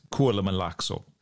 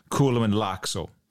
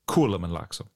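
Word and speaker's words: Kuolemanlaakso: But in Finnish, they always put an emphasis on the first syllable. So, whereas in English, you might have assumed it might be Kuolema Laakso.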